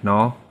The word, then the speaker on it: Nall: I believe Ryan Nall is his name, right? Nall? – Yeah. – Did I say that right? Yeah.